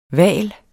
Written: hval

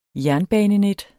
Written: jernbanenet

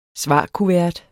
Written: svarkuvert

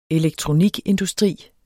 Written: elektronikindustri

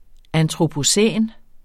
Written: antropocæn

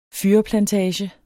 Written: fyrreplantage

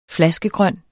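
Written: FLASKEGRØN

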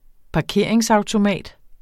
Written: parkeringsautomat

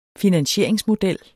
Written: finansieringsmodel

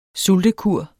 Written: sultekur

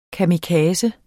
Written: kamikaze

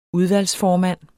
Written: udvalgsformand

